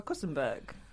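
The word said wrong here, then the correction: Kuenssberg: Is that how you say it? – Kunzberg, isn't it? – Kunzberg.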